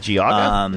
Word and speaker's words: Geauga: Was it Geauga? Is it Geauga County? – Yes, okay.